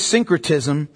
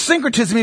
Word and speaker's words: syncretism: The second thing is syncretism. What that means is it's the creation of new philosophies and new religions. Syncretism. S-Y-N-C-R-E-T-I-S-M.